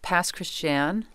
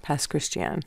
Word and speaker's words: Pass Christian: You grew up in a town in Mississippi called Pass Christian, is that right? – It's pronounced Pass Christian. – Pass Christian.